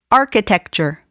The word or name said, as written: architecture